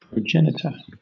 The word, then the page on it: progenitor: British English